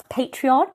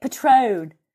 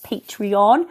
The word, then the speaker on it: Patreon: And I couldn't pronounce Patreon. And I was calling it Patron. Come and support me via Patron.